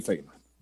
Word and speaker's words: Feyenoord: Vamos a escuchar las primeras declaraciones del Chaquito en Feyenoord, como nuestra compañera Ceci Lagos nos ha dicho, es la pronunciación correcta allá en Rotterdam. Escuchamos al Chaquito, ya oficialmente futbolista del Feyenoord.